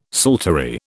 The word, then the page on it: psaltery: British English